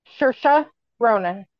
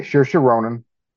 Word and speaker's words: Saoirse: Saoirse Ronan. – Okay, Saoirse Ronan.